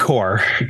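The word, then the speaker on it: corps: Yeah, basically separate from their corpse. How do you say that? C-O-R-P. – Like, the core. – Core. Thank you.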